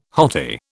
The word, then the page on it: hottie: British English